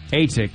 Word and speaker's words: Natick: How do I say the name of this city again? – Natick. – Natick. – Is that how you say it? – It's N-A. – Natick. – Natick. Yeah. Natick. It's in Massachusetts. I was calling it It's Natick, the long but A, but it's Natick. N-A-Y.